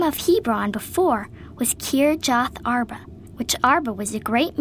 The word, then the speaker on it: Kirjath Arba: Kirjath Arba. K-I-R-J-A-T-H-A-R-B-A, spelled Sarah.